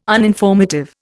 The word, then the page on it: uninformative: Indian